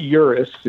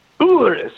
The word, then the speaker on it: Urus: If you want the Lamborghini Urus, and you got to say it right, you know, it's not Urus, if you're saying it right like the Italians are, you know, it's Urus.